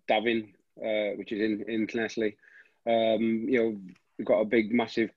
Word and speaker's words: Llanelli: You grew up in Llanelli. Is that how you say it? – Llanelli, yeah.